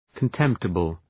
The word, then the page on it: contemptible: kən’temptəbəl